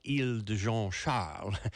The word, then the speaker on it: Isle de Jean Charles: it's spelled Isle de Jean Charles, but I'm sure it's pronounced Île de Jean Charles.